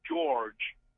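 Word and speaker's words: György: As in English, where PH means an F sound, in Hungarian, GY means the J sound. So MAGYAR is Magyar. – Magyar, or GYORGY is George.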